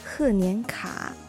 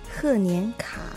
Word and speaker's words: 贺年卡: 贺年卡、贺年卡。